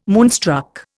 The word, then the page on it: moonstruck: Indian